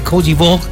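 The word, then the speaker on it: Côte d'Ivoire: Ya hay alineaciones por parte de Alemania ante Koji Vog. Así se pronuncia en François.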